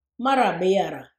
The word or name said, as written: Marra biyarra